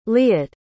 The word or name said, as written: liat